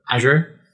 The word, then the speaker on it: Azure: and Azure, or Azure, yes, as you pronounce it.